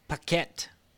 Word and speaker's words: Paquette: But other people that are cleared to be cleared from their non-compete, Renee Paquette. Is that how you say your name? – Paquette? Yeah, Paquette.